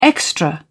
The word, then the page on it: extra: British English